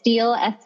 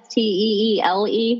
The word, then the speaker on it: Steele: It's Steele, Steele.